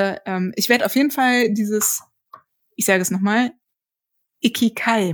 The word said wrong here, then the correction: Ikigai: machen. War das richtig ausgesprochen? – Ikigai.